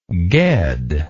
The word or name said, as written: ged